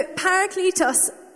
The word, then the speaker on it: parakletos: I checked with Chris how to pronounce that before, and he said it was good, so we're going to go with that one. So parakletos, and the other definitions of this word, yes, it means advocate, but it also means comforter.